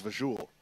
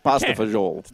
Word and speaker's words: pasta fagioli: I thought it was vajoule. – I guess you could say pasta fagioli, but no, it's pasta fagioli.